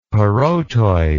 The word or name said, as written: parotoid